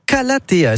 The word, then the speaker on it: Calathea: Vous m'avez donné des petits noms de plantes, hein, c'est Marenta et Calathea, est-ce que je prononce bien ? – C'est parfait, c'est parfait.